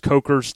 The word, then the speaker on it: Coker: Now, speaking of that, one of the first things I see, Nathan, when I type in on Instagram, C-O-K-E-R, is, the, of course, Coker's Tire Company. Or Coker, excuse me, Coker Tire Company. I always say that wrong with an S.